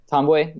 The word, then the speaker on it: Ntambwe: Is Joel, our guy Damon mentioned Joel Ntambwe? Is that how you say it? – Tomboy, yeah.